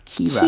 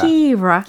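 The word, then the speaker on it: Keira: So how do you usually pronounce her name? – I, I'd say Kira. – Kira. – Kira.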